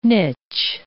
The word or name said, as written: niche